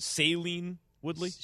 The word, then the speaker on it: Shailene Woodley: Is it Shailene Woodley? – Shailene Woodley.